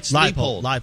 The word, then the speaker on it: Leipold: Leopold. Show you the money. – Leopold. – Yep. Lance. – Leipold. Lance Leipold. I'll get his name right.